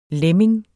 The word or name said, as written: lemming